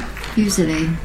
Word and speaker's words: fusilli: Fusilli.